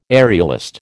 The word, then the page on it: aerialist: American English